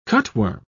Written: cutworm

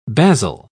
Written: basil

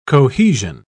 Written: cohesion

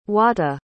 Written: huata

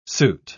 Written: suit

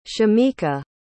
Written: shameka

